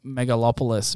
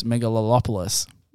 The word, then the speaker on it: Megalopolis: It's like I've been calling Megalopolis, Megalolopolis. – Yeah, you just added some syllables.